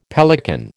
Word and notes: pelican: American English

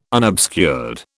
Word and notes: unobscured: British English

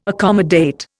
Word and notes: accommodate: Indian

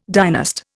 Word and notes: dynast: Indian

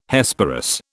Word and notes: Hesperus: British English

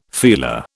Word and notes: feeler: British English

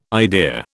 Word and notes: idea: British English

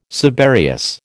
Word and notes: subereous: American English

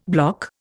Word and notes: bloc: Indian

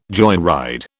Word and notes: joyride: British English

